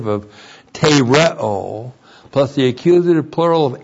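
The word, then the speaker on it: tereo (τηρέω): And then this great word, tereo. T, long E, R. E, long O, tereo.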